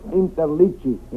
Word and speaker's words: Interliggi: My name is Joseph Interligi. I-N-T-E-R-L-I-G-G-I.